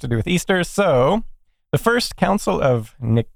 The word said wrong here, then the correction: Nicaea: I think it's Nicaea, but I'm not sure. – Nicaea, yes. I believe that's great. That sounds right.